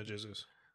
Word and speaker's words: Jesus: Because Portuguese, they pronounce the J, right? – Yeah. – Yeah. So Jesus. Is it Jesus? Would it be Jesus? – Jesus. – Jesus? – Jesus. – Jesus? – Yeah, Jesus.